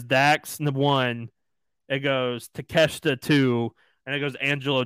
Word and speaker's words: Takeshita: But they at least gave me the pronunciation of his name, and it's Takeshita. – Takeshita. – So the I is silent. If they were correct, it's Takeshita.